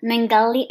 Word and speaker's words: Mengele: Yosef Mengele. – I don't think you said that right. – Mengele. It's German, so it's Mengele.